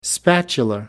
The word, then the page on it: spatula: American English